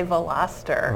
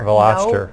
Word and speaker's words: Veloster: Have you figured out how to pronounce the name of it yet? – It is a Veloster. – Veloster. – No problem there.